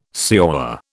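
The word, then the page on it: señor: British English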